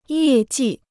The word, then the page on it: 业绩: yè jì